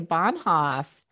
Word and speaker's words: Bohnhoff: Today, I have the pleasure of speaking with Dr. Kelly Bohnhoff.